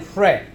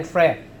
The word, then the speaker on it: Hre: My name is Paul Van Frey. How many of you can pronounce Paul Van Frey? The last name is H-R-E, but H is silent. And Paul Van Frey, I'm from the country Burma, and now it's called Myanmar.